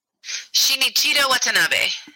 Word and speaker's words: Shinichiro Watanabe: Shinichiro Watanabe.